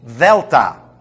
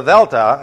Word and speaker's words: delta: The alpha, beta, gamma, delta is not the D sound. It's a T-H-E sound, the. But you put your lips as if you were pronouncing a D. Delta. It's not the D. It's not delta.